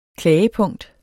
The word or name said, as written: klagepunkt